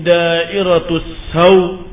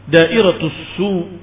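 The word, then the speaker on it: da'iratus saw (دَائِرَةُ السَّوْءِ): Faqara'athu 'ammatu qurra'il Kufah, kebanyakan para pembaca-pembaca ahli qiraah dari Kufah membacanya, 'alaihim da'iratus saw, dengan difathahkan sinnya, 'alaihim da'iratus saw. Wa qara'a ba'du qurra'il Basrah, sedangkan para ahli-ahli qiraah dari Basrah membacanya, da'iratus su, 'alaihim da'iratus su, bi dhammis sin, dengan dhammah di sin.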